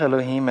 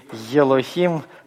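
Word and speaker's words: Элохим (אֱלֹהִים): Почему вы все хотите йод добавить к букве алеф? Елохим, хайор, не надо так. Аор, элохим, просто начинайте говорить гласное, просто не надо никакого йод там произносить.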